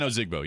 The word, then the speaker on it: Ozigbo: But as far as what I'm going to look for tonight, am I saying it right? Ozigbo? – Yeah. Yep. – Divine Ozigbo.